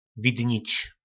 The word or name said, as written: vidnic